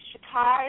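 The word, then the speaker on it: shikar (चक्कर): The English word, the Hindi word is shikar, and shikar is spelled C as in cat, A, K, K, A, R, A. But it's pronounced shikar.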